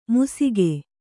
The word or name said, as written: ಮುಸಿಗೆ